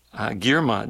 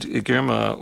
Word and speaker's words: Girma: From there, we're going to close this set off with music from the Ethiopian sax player, Girma, G-I-R-M-A, I think I might be pronouncing that right. Last name, Woldmichael, W-O-L-D-E-M-I-C-H-A-E-L, Woldmichael.